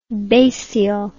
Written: basial

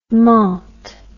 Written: menthe

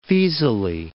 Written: Faesulae

